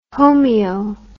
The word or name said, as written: homoeo